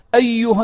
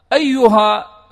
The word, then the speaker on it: أيها: قال سنفرغ لكم أيها الثقلان، أيها الأصل هي تلفظ أيها مع الألف، ولكن في ثلاثة مواضع في القرآن هذه الألف لا تكتب،